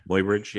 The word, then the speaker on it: Muybridge: Muybridge. Muybridge. – Muybridge, yeah.